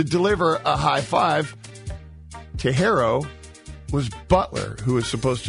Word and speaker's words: Herro: Herro, am I pronouncing that right, by the way? H-E-R-R-O, Herro. What is it? – Herro.